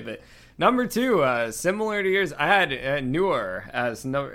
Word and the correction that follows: Nur: Is that how you say it? Nuur? Number two? – Just Nur, yeah.